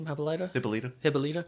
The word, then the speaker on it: Hippolyta: Hippolyta.